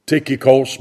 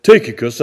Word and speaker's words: Tychicus: So Tychicus, and that's the word in the Greek, Tychicos, but Tychicus, that's how you pronounce that word, a beloved brother and faithful diakonos, servant, deacon, in the Lord shall make known to you all things.